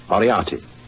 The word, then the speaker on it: Moriarty: M-O-R-I-A-R-T-Y. Moriarty.